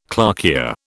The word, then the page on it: clarkia: British English